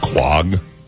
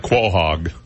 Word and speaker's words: Quahog: By the way, you know, I didn't know this, but it's pronounced Quag. – Quag? – It looks like Quahog, but it's actually pronounced Quag.